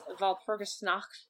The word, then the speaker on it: Walpurgisnacht: She ended up at Walpurgisnacht.